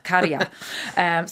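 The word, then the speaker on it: Käärijä: Kerja, am I pronouncing that correctly? – Kerja.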